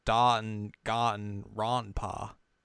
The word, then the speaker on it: Danganronpa: But like, if you think of how Japanese A's are pronounced, they're like, ah. So it'd be like, Danganronpa.